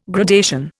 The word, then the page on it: gradation: Indian